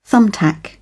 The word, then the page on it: thumbtack: British English